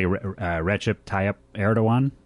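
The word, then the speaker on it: Recep Tayyip Erdoğan: See if I say this correctly. Recep Tayyip Erdogan. Is that correctly? – Yep.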